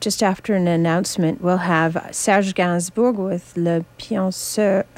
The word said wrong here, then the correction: Le Poinçonneur des Lilas: Wait, how is it pronounced? – Le Poinçonneur des Lilas.